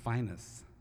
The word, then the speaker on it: Phineas: You knew him as Phineas Newborn, but down in Memphis, the word Phineas, which is spelled P-H-I-N-E-A-S, is pronounced Finus, and we call this Finus Can Be.